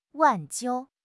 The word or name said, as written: 挽救